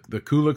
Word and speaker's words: Kula: So the Kula cloth, is that how it's pronounced, right? – It is.